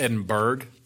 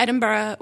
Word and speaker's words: Edinburgh: Which people, you know, it looks like it's pronounced Edinburgh, but it's not. So let's talk about that a little bit. So you went there, right? – We did. We went to Edinburgh.